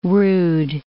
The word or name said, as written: rood